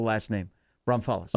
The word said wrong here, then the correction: New Braunfels: Oh, New Braunfels. – New Braunfels. Thank you.